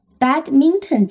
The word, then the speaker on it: badminton: 那我们再回到 Badminton 这个词。它怎么拼呢？B A D M I N T O N 这个词是这样拼的，它的读音呢是 badminton，它有三个音节，重音在第一个音节。